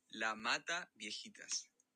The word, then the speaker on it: La Mata Viejitas: Let me play this little clip because I had my friend pronounce this for me. And once I hear him pronounce it, then I'll be able to. – Se dice La Mata Viejitas.